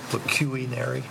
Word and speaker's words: pecuniary: I think I said that right, didn't I, City Clerk? Pecuniary? – Pecuniary.